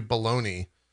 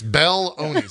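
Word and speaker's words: Belloni: It says the Hollywood Reporter editor, Matthew Baloney, Baloney, maybe is how you pronounce it, not Baloney. – Matthew Baloney. – It's Bell-oney, so it's probably, maybe it's Bell-oney.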